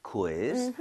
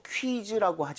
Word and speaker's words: quiz: 아, 그러잖아 읽을 때 이거 퀴즈라고 하지 마시고요. 우이를 따로 다 발음해야 돼요. – quiz. – quiz. 그 대신에 아. 빠르게 하는 것 뿐이에요. So, quiz 아니라 quiz. quiz. 좀 이런 식으로 퀴 말고요.